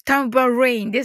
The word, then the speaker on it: tambourine: タンバレインですね。